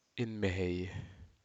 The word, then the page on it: inmehei: Aneityum